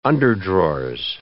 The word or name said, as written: underdrawers